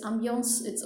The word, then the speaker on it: ambiance: The word is ambiance. Okay, so it's ambiance. Now in French it's ambiance. So in British English you will find people trying to keep that French essence. That is why it's ambiance.